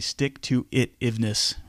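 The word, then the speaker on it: stick-to-itiveness: the stick-to-it-ness, am I saying that right? Stick-to-it-iveness, right? The ability to, yeah, you got it.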